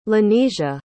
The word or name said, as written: lanesia